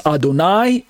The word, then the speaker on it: Adonai: On the right side, it's Adonai, A-D-O-N-A-I, pronounced as Adon plus the word I, like your eyeball I.